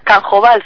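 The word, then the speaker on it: Q'anjob'al: La reapertura, se refiere a la radio, se está realizando mediante el sistema de usos y costumbres del derecho maya, yo también lo voy a pronunciar mal, Anjob Al. – Pues es Canjobal, si no estoy mal, creo que...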